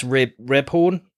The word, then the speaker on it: Rebhorn: It's the great James Rebhorn.